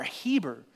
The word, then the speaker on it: Heber: Even you'll see his name written in the New Testament as Heber, H-E-B-E-R, Heber, all right? And so it very well might be that there was that's how you pronounce his name, not Eber, but Heber here.